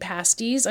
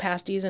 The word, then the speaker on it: pasties: Joe said, UP Michigan pasties. I'm pretty sure it's pronounced pasties and not pasties, because that would be a whole different thing.